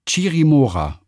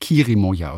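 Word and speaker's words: Chirimoya: Vom Kölsch-Peruaner soll ich übrigens noch ausrichten, dass die fantastischste Frucht aller Zeiten Chirimora und nicht Kirimoya ausgesprochen wird.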